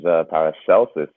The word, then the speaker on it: Paracelsus: Hey guys, this is Paracelsus here. Luke actually mispronounced it.